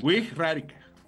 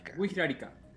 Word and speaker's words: Wixárika: Wixárika. – Wixárika.